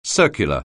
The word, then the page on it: circular: anglais